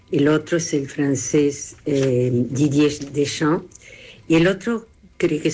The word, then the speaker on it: Didier Deschamps: Que mejor dijo Didier Deschamps.